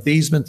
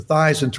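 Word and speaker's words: Theismann: We think by simply changing the pronunciation of your last name from Theisman to Theisman to rhyme with Heisman, we can get you that trophy.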